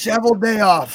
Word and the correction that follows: Cheveldayoff: Sh- Cheveldayoff.